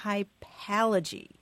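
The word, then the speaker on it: hypallage: Well, here's how you spell it. It's H-Y-P-A-L-L-A-G-E, hypalogy.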